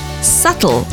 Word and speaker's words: subtle: The B in subtle is silent. So it's subtle, not subtle.